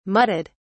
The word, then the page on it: mudded: British English